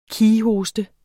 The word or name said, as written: kighoste